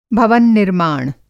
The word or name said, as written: भवन-निर्माण